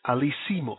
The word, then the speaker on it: Alcimus: This person's name is Alisimus. Alisimus. His name is spelled A-L-C-I-M-U-S.